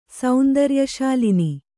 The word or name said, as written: ಸೌಂದರ್ಯಶಾಲಿನಿ